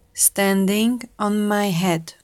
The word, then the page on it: standing on my head: slow